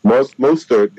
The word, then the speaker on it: Mostert: Mostert, exactly.